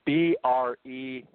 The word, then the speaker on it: Brecheisen: Okay, it's Brecheisen.